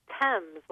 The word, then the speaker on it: Thames: It's Thames, like the river.